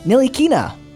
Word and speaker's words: Ntilikina: Turning 24 years old today is a current NBA point guard for the Dallas Mavericks, Frank Ntilikina. Is that right? – Yes.